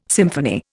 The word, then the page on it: symphony: Indian